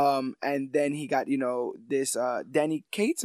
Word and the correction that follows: Donny Cates: Am I saying that right? – Donny Cates. – Donny Cates, yeah.